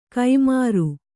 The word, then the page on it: ಕೈಮಾರು: Kannada